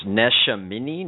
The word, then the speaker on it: Neshaminy: Neshaminy.